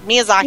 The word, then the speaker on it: Miyazaki: And then what's really beautiful is as more people are becoming aware of foreign films and foreign things, Hayao Miyazaki, I can never say his first name, but Miyazaki. – You said it right.